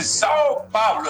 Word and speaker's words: São Paulo: ¿Vio cómo pronuncia Lerner? Dice Sao Paulo. ¿Está bien dicho así? – Perfecto.